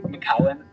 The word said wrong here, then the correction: McEwen: Uh, McEwen, yes.